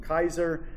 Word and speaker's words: Keyser: Kaiser is not spelled like the role. It's spelled K-E-Y-S-E-R.